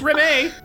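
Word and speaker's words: Rémy: It's pronounced Remy.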